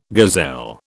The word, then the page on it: gazelle: British English